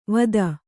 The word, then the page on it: ವತ್ತಾ: Kannada